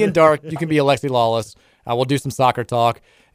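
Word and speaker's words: Alexi Lalas: Can I be like Alexi Lawless? – Yeah. – Is that how I say his name right? – Yeah.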